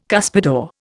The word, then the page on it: cuspidor: Indian